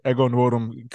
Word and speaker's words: Ego Nwodum: Ego Nwodum cutting her extra well done steak. – Yeah, I actually don't think you pronounce the N. It's just Ego. – Oh, just Ego Nwodum?